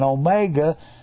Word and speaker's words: omega: Then you've got, so you got O-P-R-S-T-U. The O looks like our O. That's an Omicron. It's pronounced ah. And this down here is an omega. That's an O, and it's pronounced O.